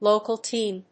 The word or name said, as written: local team